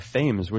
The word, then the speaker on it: Thames: I believe he actually goes by Thames, which is aggravating. – Yeah, right. That's right. I didn't learn about a river in England to mispronounce it.